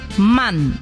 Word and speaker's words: Mann: Mann. M-A-N-N. Além de uma pronúncia diferente no inglês, destacando o A aberto na pronúncia.